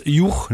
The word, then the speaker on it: Hugh: Auch im Englischen, wie spricht sich das aus? Juch, ne? H-U-G-H geschrieben, alter Vorname oder überhaupt noch moderner Vorname.